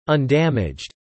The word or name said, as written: undamaged